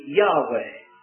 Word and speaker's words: Yahweh: But Bible scholars are pretty well agreed that the pronunciation should be Yahweh.